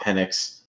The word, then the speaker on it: Penix: UW, I mean, Michael Penix, it's pronounced Penix,